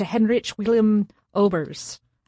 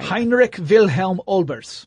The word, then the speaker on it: Heinrich Wilhelm Olbers: That happened on March 29th, 1807, thanks to Heinrich Wilhelm Olbers. I don't think I said that right. – Heinrich Wilhelm Olbers.